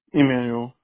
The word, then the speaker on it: Emanuel: Is that your full name, M-A-N-N-Y, or is it Emmanuel? – Emmanuel, yeah. – Okay, can you spell that for me, please? – E-M-A-N-U-E-L.